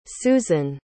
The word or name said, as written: suzan